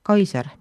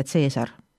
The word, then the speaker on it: Caesar: Antiikaja hääldus C-tähel oli kõikel K, seega Kikero, mitte Cicero, Kaiser, mitte Caesar.